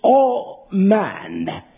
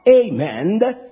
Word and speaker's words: amen: It is spoken like this. Listen, it is all man. It is not amen, because that is the name of a Greek god. It is all man.